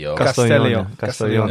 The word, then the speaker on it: Castellón: Castellón.